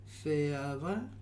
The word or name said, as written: Feava